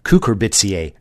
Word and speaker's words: Cucurbitaceae: All cucumbers, which are members of the Cucurbitaceae family. I left a syllable out. Cucurbitaceae family.